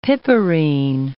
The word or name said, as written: piperine